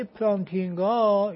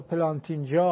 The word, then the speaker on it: پلانتینگا: لذا ببینید آقای پلانتینگا یا پلانتینجا، حالا هر دو تلفظ رو میکنن اسمشون.